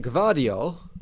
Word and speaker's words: Gvardiol: Coming in could be RB Leipzig's Josko Gvardiolo.